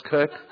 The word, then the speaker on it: Koch: You know, my last name is spelled K-O-C-H and it's pronounced Cook.